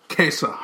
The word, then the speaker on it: Käse: How do you say that word? – Kesa? – Kesa. Harzer Kesa!